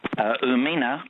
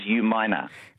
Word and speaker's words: Umina: And also, Umina, like you said before, is U-minor. – U-minor, that's right. But it was, I mean, it had been spelt out phonetically by Stuart. So U-minor, but people sometimes say U-mina, Umina.